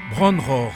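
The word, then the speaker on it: Bron-Yr-Aur: Bron Roar. C'est un mot gallois, ça se prononce comme ça.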